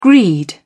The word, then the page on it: greed: British English